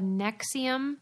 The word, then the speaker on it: NXIVM: Because I feel like she was a member of a group called Nexium, but they spell it real weird. – N X I V M. Yeah and oh, there's oh, like this, the V is the U, like the old school.